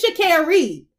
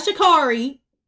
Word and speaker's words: Sha'Carri: And it's Sha'Carri, not Sha'Carri.